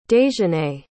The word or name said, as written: dejanae